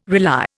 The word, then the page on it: rely: Indian